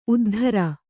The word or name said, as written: ಉದ್ದರಿ